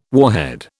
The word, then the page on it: warhead: British English